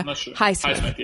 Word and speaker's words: Highsmith: Patricia Highsmith.